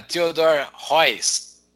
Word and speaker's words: Theodor Heuss: Certificado em liderança pela Theodor Heuss. Falei certo? Sim.